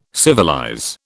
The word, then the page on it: civilize: British English